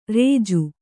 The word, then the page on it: ರೇಜು: rēju; kannada